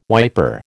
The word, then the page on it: wiper: American English